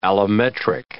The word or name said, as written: allometric